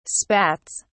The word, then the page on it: spats: British English